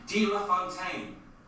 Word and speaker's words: De La Fontaine: De La Fontaine. – De La... – It's D. Sounds like Dealer Fontaine.